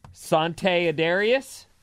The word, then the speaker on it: Sante Adarius: Sante Adarius? Am I saying that right? – It sounds perfect to me.